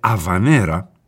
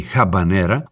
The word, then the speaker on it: αβανέρα: Θα υπενθυμίζω για μια ακόμη φορά ότι προφέρεται αβανέρα και όχι χαμπανέρα, όπως συνηθίζουμε μερικές φορές, γιατί πρόκειται για έναν χορό από την Αβάνα και τον προφέρουμε στα ισπανικά.